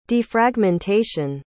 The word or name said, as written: defragmentation